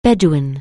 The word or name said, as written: bedlam